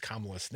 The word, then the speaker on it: Kamala: And Bryant, I believe the other day, also texted in and said it hurts when Crudy pronounces Kamala's name.